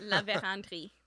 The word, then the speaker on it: La Vérendrye: Yeah, how do you say that? – La Vérendrye. – La Vérendrye. Okay, yeah.